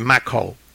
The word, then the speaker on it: Macau: He says that his name rhymes with tobacco, so it's Henry Macau, okay?